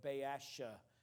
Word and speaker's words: Baasha: The north by this time had a king, and we may not spend a lot of time on him, but his name is the name of Baasha, is the way that I pronounce it.